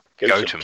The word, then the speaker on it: Gotham: It's spelt the same as Gotham, yes, G-O-T-H-A-M, but it's pronounced Gotham.